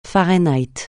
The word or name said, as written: Fahrenheit